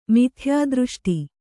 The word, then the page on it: ಮಿಥ್ಯಾದೃಷ್ಟಿ: mithyādřṣṭi